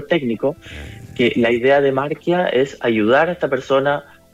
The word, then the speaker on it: Marquia: Sí, Marquia, que se escribe con Q. – Marquia. – IA.cl, sí. – Ah, punto CL, ya, sin una U entre medio, como diría el idioma español. Marquia, así nomás.